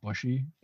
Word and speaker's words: Bouchy: But I think, like, from the beginning when they had the first meeting with the coaches and Brett, they call him Brett Bushy, but his last name is spelled Bouchy. So the fact that it doesn't rhyme with douchey just tweaks.